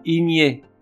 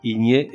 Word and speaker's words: igné: Igné i g e. adjectif qui admet les deux prononciations igné et igné.